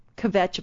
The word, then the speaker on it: kvetch: kvetch about him. And so did I say that right? – Yeah, kvetch. – Yeah. – I'm not Jewish, but I know the word anyway. – You said it right.